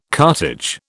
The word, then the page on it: cartage: British English